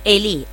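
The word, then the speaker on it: Elí: Bien, Eli, ahí está, con el acento en la I.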